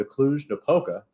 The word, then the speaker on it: Cluj-Napoca: We'll be going to Cluj-Napoca.